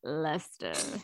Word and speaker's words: Leicester: It's spelled Leicester. – Yes, but it's pronounced Leicester.